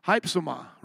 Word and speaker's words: Hypsoma: Kind of sounds like hype. Has hype right in there, doesn't it? Hypsoma, right? Isn't that how you pronounce it? H-Y-P-S-O-M-A.